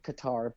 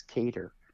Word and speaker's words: Qatar: Most people call it Qatar, but apparently the proper pronunciation is Cater, which no, doesn't work for me.